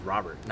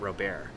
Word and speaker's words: Robert: I actually learned it was Robert, not Robert.